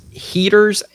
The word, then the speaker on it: Heaters: So there was another strange encounter that allegedly took place in the town of, I think it's pronounced Heaters, H-E-A-T-E-R-S, West Virginia, which is about 5 miles north of Flatwoods.